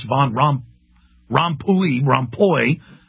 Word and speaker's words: Van Rompuy: That's what Van Rompuy, I've been mispronouncing it as Van Rompuy because I saw them in the British Parliament calling him Van Rompuy. It's Van Rompuy, the new head of the European Union admits global governance, that the key to it is the global carbon tax.